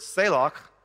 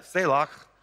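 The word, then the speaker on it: selach (צלח): But to me, what prosperity, the word literally in the Hebrew is the word selach, and you got to say it like that. You got to do that, selach, okay? Like, yeah, popcorn kernel stuck in the back of your throat, I don't know.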